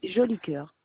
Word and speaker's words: Jolicoeur: Mon nom de famille, c'est Joli Cœur. – Comment ça s'écrit? – J-O-L-I-C-O-E-U-R. Joli Cœur.